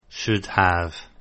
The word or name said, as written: should have